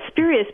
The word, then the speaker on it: spurious: But yeah, for example, the word spurious, S-P-U-R-I-O-U-S. Which comes from a Latin word, incidentally, that means illegitimate child. But spurious, meaning, you know, fake or not really real. That word comes, it's pronounced spurious. It rhymes with curious and furious.